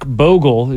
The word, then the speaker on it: Bogle: Jack Bogle is widely credited as the father. – It's interesting that you got the name wrong, but you spelled it. – Because I thought it was Bove, but it's Bogle.